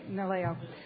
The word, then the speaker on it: NALEO: She has a position with the education part of the Hispanic leadership, Nahalo, am I saying it right? – Naleo.